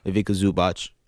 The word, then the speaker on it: Ivica Zubac: Ivica Zubac. Is that how you say his name? – Sure. – Okay.